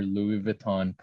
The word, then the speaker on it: Louis Vuitton: I want to sign your Louis Vuitton purse.